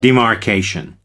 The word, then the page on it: demarcation: American English